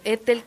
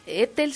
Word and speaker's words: Ethel: Pero Ethel Krause K, Ethel se escribe E-T-H-E-L y Krause se escribe con K de kilo, K-R-A-U-Z-E.